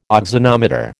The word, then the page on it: auxanometer: American English